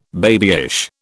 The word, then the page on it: babyish: British English